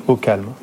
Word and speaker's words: OKLM: Ce ne sont plus les mots, les lettres O, K, L, M, mais au calme, qui sont entendus, qui sont prononcés.